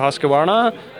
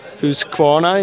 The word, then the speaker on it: Husqvarna: We've been doing some test riding today with, for uh, Husqvarna, Husqvarna in Swedish, uh, Husqvarna. How do you say it? Husqvarna. Husqvarna. That's yeah, that's good. That's good.